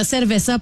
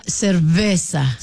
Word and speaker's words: cerveza: Puis là, il y a quelqu'un qui a dit au 6-12-12, faut que les Québécois arrêtent de prononcer cerveza avec un V, même si ça se lit comme ça, ça s'écrit comme ça. Mais le V en espagnol se prononce comme un B.